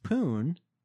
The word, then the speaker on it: Pune: Meanwhile, in the Indian city, I mean, I guess it's pronounced Pune. – Yeah. – P-U-N-E. – Correct.